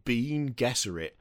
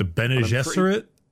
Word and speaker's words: Bene Gesserit: And there's things like psykers in the form of I thought it was pronounced Bean Gesserit, which is not how you pronounce that. – The Bene Gesserit? – Yeah. Yeah, it's not Bean Gesserit.